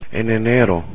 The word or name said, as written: en enero